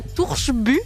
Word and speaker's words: Torsby: Alors attends, je vais essayer de prononcer le truc comme tu l'as dit. À Torsby, c'est ça, il y a un tunnel, ça ressemble à quoi? – Ouais, exactement.